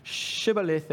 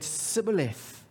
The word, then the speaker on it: Shibboleth: And people from Ephraim couldn't make the sh sound that they should have. And instead of saying Shibboleth, they said Sibboleth.